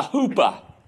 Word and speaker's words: chuppah: If you've ever been to a Jewish wedding, you will notice that they get married under what's called a huppah. All right? It's spelled C-H-U-P-P-A-H. All right? Tupa. But you don't say the C. All right? So it's a huppah.